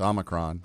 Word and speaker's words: Omicron: Oh, it's Omicron. Omicron. – No, it's Omicron. – Omicron. – It's in the Greek alphabet, it's the short O, Omicron. Omega is the long O.